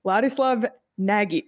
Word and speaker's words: Ladislav Nagy: So since 1996, 97, Clayton Keller now joins that list, but also on the list, Ladislav Nagy. Am I saying that right? – You are saying that right.